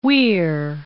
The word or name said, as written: Tyne and Wear